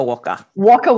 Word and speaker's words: Waka Waka: Waka Waka. – Waka Waka.